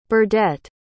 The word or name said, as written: Burdette